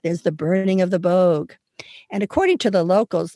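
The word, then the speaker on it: Böögg: One is called Sexalointen, and it's spelled S-E-C-H-S-E-L-A-U-T-E-N. It's celebrated in Zurich in Switzerland, and there's this 12-foot-tall creature called the Boog, B-O-O-G-G.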